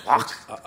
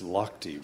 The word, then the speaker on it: Lochte: How would you say that? – Lock. – I know that it's Lochte, but that's just because I spend a lot of time on the road and I listen to a little sports talk radio.